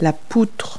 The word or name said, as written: girder